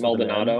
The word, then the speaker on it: Maldonado: Starts Maldonado.